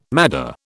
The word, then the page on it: madder: British English